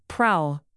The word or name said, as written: prowl